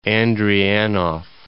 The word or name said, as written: Andreanof Islands